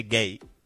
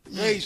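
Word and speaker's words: Guéhi: se escribe Guehi, pero creo que se dice Gay. – Gay, sí.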